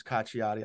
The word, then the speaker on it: Cacciotti: Yeah, Center Grove kids, probably Cacciotti. – Cacciotti, is how you pronounce it? – Looking at it, looks Italian. So the double C would probably be a CH. So it would come off as Cacciotti.